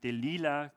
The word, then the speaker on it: Delilah: Ich nenne sie jetzt mal Delilah, vielleicht kennt ihr auch dieses Lied, ich weiß nicht, Delilah klingt sehr ungewöhnlich, deswegen bleibe ich mal bei dieser englischen Aussprache.